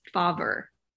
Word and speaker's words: Fauver: Nope, but that's cute. – That's exactly how I say it. That's probably why you say it like that. – Oh my gosh. No, it's Fauver.